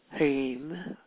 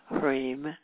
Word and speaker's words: Hrim: Her mantra is Hrim, Hrim, H-R, H-R, long I-M, Hrim.